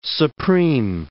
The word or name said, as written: supreme